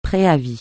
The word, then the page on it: préavis: francais